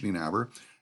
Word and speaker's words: Nienaber: My name is spelled like it sounds, Paige Nienaber.